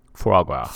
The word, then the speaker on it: foie gras: Frog raw. Did I say that right? Foie gras. – Foie gras? – Yeah.